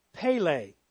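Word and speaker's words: Pele (פלא): I pronounced this word as Pele. And it reminded me of the great Brazilian soccer player, Pele.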